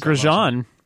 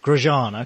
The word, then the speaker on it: Grosjean: Which Englishman's 10th attempt to win Wimbledon was scuppered by Sebastian Grosjean? I'm not sure how you pronounce it. – Grosjean. – Grosjean, okay.